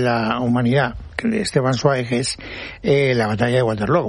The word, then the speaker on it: Zweig: Por el camino de bien, ahora hay que decir Zweig. – Zweig. – Esteban Zweig. – Es así como se pronuncia en alemán.